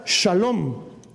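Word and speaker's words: shalom (שלום): Ahora, la palabra que te están traduciendo ahí por paz es la palabra hebrea que tú y yo ya conocemos, que se pronuncia shalom.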